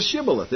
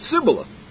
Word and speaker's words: Shibboleth: And said they unto him, Say now Shibboleth. And he said, Sibboleth. For he could not frame to pronounce it right.